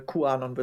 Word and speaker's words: QAnon: Oder, ja, also QAnon wird es ausgesprochen.